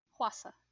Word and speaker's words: Hwasa: The next one is Maria by, I can't say her name right. – Hwasa. – Hwasa, okay. Makes me nervous to get her name right.